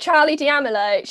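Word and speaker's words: Charli D'Amelio: Really worried. I say it wrong. It's Charlie D'Amelio. She's like the most famous TikToker, I'd say. – Charlie D'Amelio, got her.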